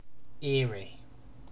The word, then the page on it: eerie: British English